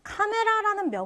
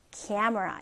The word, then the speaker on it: camera: 자, 다음은 카메라라는 명사가 나옵니다. 자, camera. 자, 발음 해볼까요? camera. camera. 이렇게 발음이 돼요. 그러니까 카메라. 이렇게 발음하는 게 아니고요. camera. 네, 이렇게 됩니다.